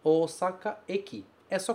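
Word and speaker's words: Eki (駅): Como é que ele se pronuncia? Eki. Eki, estação, ok?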